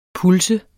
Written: pulse

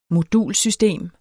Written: modulsystem

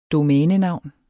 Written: domænenavn

